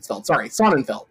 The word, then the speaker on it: Sonnenfeld: Or Sonnenfeld, I don't know how you pronounce it. No, it's Feld, Sonnenfeld.